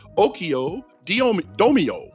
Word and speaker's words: oikodomeo: That Greek word was okio domio. Ooh, I did a good one on that one, y'all. Okio domio. That's spelled O-I-K-O-D-O-M-E-O.